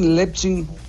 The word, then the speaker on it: Leipzig: Leipzig, Leipzig.